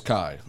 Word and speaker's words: Chi: I know the CHI is Kai.